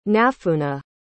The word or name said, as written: nafuna